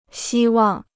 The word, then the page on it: 希望: xī wàng